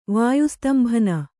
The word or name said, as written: ವಾಯುಸ್ತಂಭನ